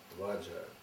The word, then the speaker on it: ваджра: Потому что ваджра читается за один слог. Ом ваджра кили килая ум пэ.